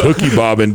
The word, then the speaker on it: hooky bobbing: It's hooky bobbing, dude. – It's hooky. Really? – Hooky. H-O-O-K-Y.